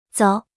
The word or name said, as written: 走